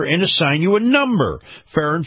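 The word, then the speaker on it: Farah: And this portion of the program is brought to you by our friends at Farrah & Farrah. Now, Farrah & Farrah is spelled F-A-R-A-H, F-A-R-A-H.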